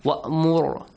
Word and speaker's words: وَأْمُرْ: Only the letter Ra has to be pronounced correctly. وَأْمُرْ heavily. وَأَعْرِضْ ri lightly. Yes?